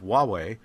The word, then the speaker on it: Huawei: I'm trying to figure out how to pronounce H-U-A-W-E-I. Right? It looks like Who Are We, but it's not. It's Huawei,